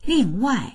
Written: ling4wai4

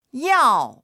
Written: yao4

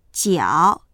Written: jiao3

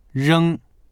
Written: reng1

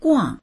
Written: guang4

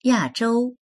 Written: ya4zhou1

